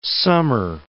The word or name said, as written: summer